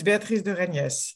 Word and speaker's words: Béatrice de Reyniès: My name is Béatrice de Reyniès. It's a French name. – Oh, with a S at the end.